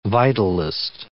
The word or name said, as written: vitalist